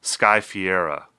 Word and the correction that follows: Ferreira: did I say it right that time? – No. – Well, how do you say it? – Sky Ferreira. – Sky Ferreira.